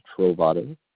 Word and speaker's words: Trovato: Richard Trovato.